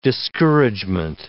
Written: discouragement

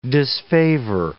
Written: disfavor

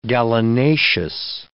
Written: gallinaceous

